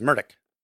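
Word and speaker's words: Murdaugh: He says Murdoch. – Yep. And I've been told that Murdoch was the more formal way to pronounce his name, but Murdoch was how they pronounced it amongst friends.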